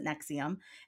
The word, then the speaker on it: NXIVM: It's spelled N-X-I-V-M, but they pronounce it Nexium.